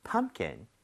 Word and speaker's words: pumpkin: 대신에 펌킨 할 때는 P 발음이 거의 안 된다고 보시면 돼요. 왜냐면은 요 사이에 자음 M하고 K 사이에 샌드위치가 돼서 그래요. 오케이? – 네. – 자, 한번 해볼게요. U의 강세를 주고 이런 식으로요. Pumpkin, pumpkin.